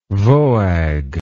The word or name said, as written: voag